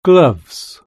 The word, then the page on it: gloves: British English